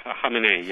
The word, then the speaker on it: Khamenei: Khamenei, yeah. – Khamenei, thank you. I always get the stress.